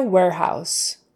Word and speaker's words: warehouse: Data warehouse, warehouse, air, air, air, like the air you breathe. A R E in this case is going to be pronounced air, warehouse, O U. It's ow, ow, ow. And that S is an S, not a Z warehouse, data warehouse.